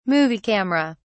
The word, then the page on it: movie camera: British English